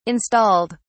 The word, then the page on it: installed: British English